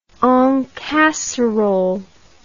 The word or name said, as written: en casserole